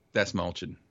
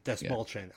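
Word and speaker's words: Dastmalchian: It's like Dashman or something. But anyway, real quick, Desmalchin. – Desmalchin.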